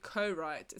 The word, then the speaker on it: Corite: It's called Co-Write. It's spelled C-O-R-I-T-E. So like Co-Write, but without the W kind of thing.